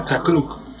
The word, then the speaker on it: Chakruk: El juego se llama Chacruc. – Bien, chacruc, me suena una comida de este bien. – Me suena a chucrut.